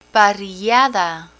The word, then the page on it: parrillada: Spanish